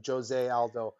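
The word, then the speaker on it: José Aldo: Like, it's spelled like right. Jose, but in Brazil they pronounce the J, so Jose Aldo.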